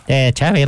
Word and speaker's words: Xavi: Xavi, like chocolate. Pronunciation in Netherlands. – Sí. – It's Xavi like chocolate. – Xavi like chocolate. Like chocolate, like the cake.